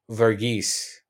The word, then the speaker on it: Varghese: So for myself, for Paul Varghese, did I say it right? – You nailed it.